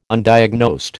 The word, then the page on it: Undiagnosed: American English